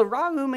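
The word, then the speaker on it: ra'um (רחום): Now, this is the Hebrew word ra'um, ra'um. If you want to spell it, it's spelled R-A-K-H-U-M, R-A-K-H-U-M. From what I understand, it's pronounced ra'um.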